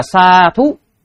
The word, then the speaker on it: sādhu: Sa thú. Đấy, cái từ nó là như vậy đó, sa thú. DH chứ không phải là TH.